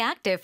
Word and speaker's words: active: 근데 active 뒤에 부까지 발음하지 않습니다. – Active. – Active. – 아주 짧게 the. – active. – Active. Active. – 그 부라고 하고 싶겠지만 거기서 딱 끊어서 active.